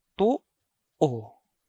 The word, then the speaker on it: to-o: The second pronunciation for the word is to-o, to-o. Again, to-o, which means the right side. So, to-o is the opposite of wala. The way it is pronounced is that the first vowel has a glottal catch, so it is stopped, while the second vowel is a normal vowel.